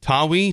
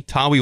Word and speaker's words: Tawee: Tawi, T-A-W-E-E, Tawi Walker, 24 carries, 198, and 3 touchdowns against Rutgers.